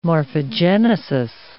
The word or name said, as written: morphogenesis